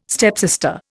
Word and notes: stepsister: Indian

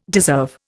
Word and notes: deserve: Indian

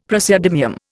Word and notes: praseodymium: Indian

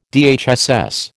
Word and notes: DHSS: American English

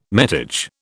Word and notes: metage: British English

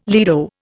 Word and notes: lido: Indian

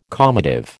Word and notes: calmative: American English

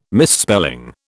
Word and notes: misspelling: British English

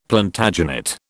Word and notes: Plantagenet: British English

